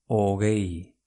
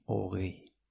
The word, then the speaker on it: hogei: Ogei. H-O-G-E-I. Ogei. Venga, ¿cómo se escribe 11 en euskera? Amaika. Amaika. H-A-M-A-I-K-A.